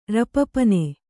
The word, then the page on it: ರಪರಪನೆ: raparapane